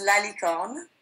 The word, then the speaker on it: La Licorne: So it's La Licorne.